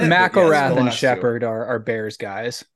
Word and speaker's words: McIlrath: McIlrath. – McIlrath, yeah.